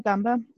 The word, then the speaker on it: Dumba: And I think especially after seeing um is it Dumba? I always feel like I'm saying his name wrong. – That uh, Dumba, yeah.